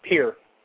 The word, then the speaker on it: Pierre: The capital of South Dakota is spelled Pierre, but pronounced Peer.